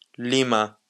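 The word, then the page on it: lima: Basque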